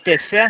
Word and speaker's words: Tefé: Nós estamos no centro de Tefé, assim que se pronuncia.